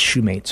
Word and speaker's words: Shumate: His name is spelled E-L-A-N. And our last name is Shumate, so S-H-U-M-A-T-E.